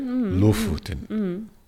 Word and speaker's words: Lofoten: such as Lofoten? Am I pronouncing that correctly? – Yeah, okay, great. Lofoten. Okay.